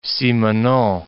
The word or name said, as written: Simenon